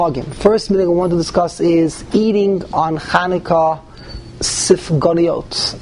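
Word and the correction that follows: Sufganiyot (סופגניות): To eat Sufganiyot.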